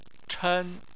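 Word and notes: chen1: tone 1

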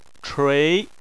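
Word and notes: chui2: tone 2

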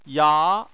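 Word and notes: ya: tone 2

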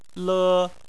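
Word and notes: le: tone 1

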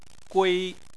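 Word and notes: gui1: tone 1

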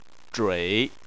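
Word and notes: zhui: tone 3

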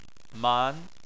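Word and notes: man: tone 2

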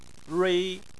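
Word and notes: rui: tone 1

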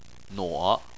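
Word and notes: nuo3: tone 3

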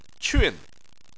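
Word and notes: qun: tone 4

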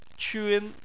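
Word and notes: qun: tone 1

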